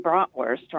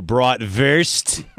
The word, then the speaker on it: bratwurst: I want to say that you guys are both saying bratwurst wrong. – What do you mean? – The W is pronounced with a V. – So bratwurst? – Yes.